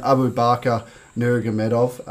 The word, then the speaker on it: Nurmagomedov: 10 points for pronunciation. His first name, Abu Bakr Nurmagomedov. – I think you've done that well.